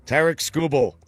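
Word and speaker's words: Tarik Skubal: Tarek Skubal.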